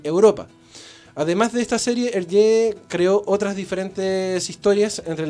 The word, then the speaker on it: Hergé: Nació en Bélgica el 22 de mayo de 1907 y fue obviamente un eh, historietista belga más conocido con el seudónimo artístico de Hergé, porque esa era la pronunciación en francés de sus iniciales invertidas, RG, de Remy y Georges.